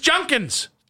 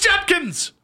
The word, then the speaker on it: Judkins: It's Junkins. Junkins, Junkins, Junkins. No, it's Judkins.